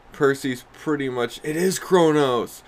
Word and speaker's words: Kronos: I think I said Kranos.